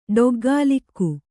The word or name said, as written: ಡೊಗ್ಗಾಲಿಕ್ಕು